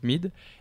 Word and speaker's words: Myd: donc ça s'écrit MYD mais ça se prononce Mid,